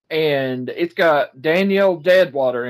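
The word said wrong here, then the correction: Deadwyler: in it, or Deadweiler, sorry, Deadweiler, I don't know why I said Deadwater, I mispronounced, my bad.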